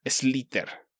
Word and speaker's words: Slither: Slither. Se escribe S-L-I-T-H-E-R.